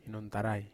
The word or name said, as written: hinontarai